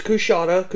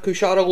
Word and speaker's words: Coushatta: Well, the post-war economy was less supportive of traveling carnival exhibits, and Brooks retired to, I haven't a clue how to say this, Coushatta? – It's Coushatta. Coushatta, Louisiana. – Okay, thank you.